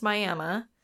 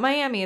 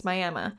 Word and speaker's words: Miami: So Picher, Oklahoma is located 8 miles north of, and don't butcher me on this, it's spelled like Miami, Florida, but it's pronounced Miami. That's where my coworker's from. She's from Miami. So I have a previous resident telling me it's not Miami, it's Miami.